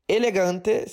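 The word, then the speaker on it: L-Gante: Elegante. Elegante se escreve L, a letra tracinho Gante.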